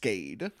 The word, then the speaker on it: Schkade: I'm going to wager a guess, and it's pronounced Skade. – I see it all the time. – Yeah. S-C-H-K-A-D-E.